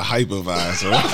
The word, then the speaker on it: hypervisor: Man, I got this Hepavisor from Groove City. – Hepavisor? – Is that how you really pronounce it? – Hepavisor. – You're thinking about work when you're talking about a hypervisor.